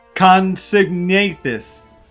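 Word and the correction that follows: Compsognathus: Very close. Comsognathus, with a C-O-M, actually silent P, Comsognathus, is a little tiny guy, which is a carnivore, but was about the size of a chicken.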